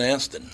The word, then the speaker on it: Astin: Sean Astin, who was in The Goonies. – Sean Astin. – It says Austin. – Does it really? I thought it was Sean Astin. – He probably changed it. – Maybe it is pronounced. – A-S-T-I-N.